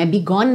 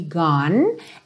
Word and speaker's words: gone: Também não é bigone, né? cuidado que esse é final não é pronunciado, então will be gone and so restless nights.